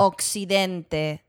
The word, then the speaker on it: Occidente: Is that how you say it? – Occidente. – Yeah, Occidente. That's the West.